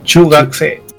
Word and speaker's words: chugakusei: Luego de los 12 a los 15 es la escuela media o la secundaria, que es el chugakusei. ¿Sí lo pronuncié bien? – Chugakse. Ajá, se la U, acuérdate que se, ajá.